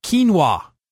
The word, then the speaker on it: quinoa: So today I'm talking about quinoa. Now, you wouldn't guess you'd pronounce that as Q-U-I-N-O-A.